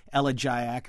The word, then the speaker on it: elegiac: In this case, you can say elegiac or elegiac.